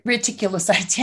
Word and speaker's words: reticulocytes: And what I found interesting was on the reticulocytes. Is that how you say it? You know, I can't say these words. – Reticulocytes. Yeah.